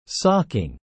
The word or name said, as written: socking